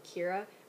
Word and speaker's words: Kiera: So my name is spelled K-I-E-R-A, not like your typical Kiera, which is K-E-I-R-A or K-I-R-A. It's K-I-E-R-A. So my name is actually supposed to be pronounced Kiera, which is like key, like lock and key, air, you know, oxygen, air, and then so Kiera.